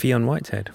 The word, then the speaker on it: Fionn Whitehead: Dunkirk, hitting cinemas worldwide this Friday, the 21st of July, starring, how do I pronounce this, is it Fionn Whitehead? – Fionn Whitehead.